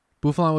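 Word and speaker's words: Bouffalant: Buffalant.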